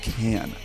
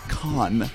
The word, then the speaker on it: Cannes: By the way, it's pronounced Can, not Con, not Cans. – Shaka Khan. – Not Canis.